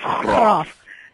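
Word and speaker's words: Graff: Graaf Diamonds, and I don't know if I'm pronouncing it right. Somebody said it's Graaf. Yes, Graaf Diamonds,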